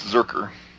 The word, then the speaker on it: Zerker: It rhymes with berserker, right? – Yeah, according to our forums, it's Zerker.